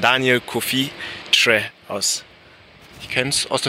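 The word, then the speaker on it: Kyereh: Aber für alle KY wird im Ghanaischen Tsch ausgesprochen und deswegen ist ganz korrekt Tre.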